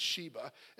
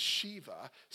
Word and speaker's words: Shiva: And I actually mispronounced it a couple weeks ago. I said Sheba. It's Shiva. Sitting Shiva.